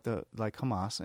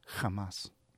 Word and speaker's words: Hamas: So Roger, you know, when you have these outfits like Hamas and blah, blah, blah, and Roger goes, it's Hamas. – Oh, what he said? – Yeah. He corrected him on its pronunciation of how ironic I messed up pronunciation.